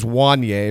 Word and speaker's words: Wanye: By the way, it is Wanye, or it rhymes with Kanye.